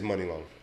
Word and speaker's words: Money Long: I thought it was Money Long. It is. And then I said that on the radio. – It is Money Long. I hear them pronounce it as Money.